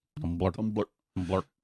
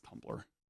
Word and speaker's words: Tumblr: M-B-L-R. – It's Tumblr. Tumblr. – Tumblr. That's how you pronounce it, too. I've been pronouncing it wrong. No, it's Tumblr.